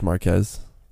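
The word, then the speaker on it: Marques: Yeah, I was watching Marquise Brownlee's review because he got a Model S Plaid and Marquise. It has the yoke. And did I say it wrong? Or did I say it right? – It was Marquez.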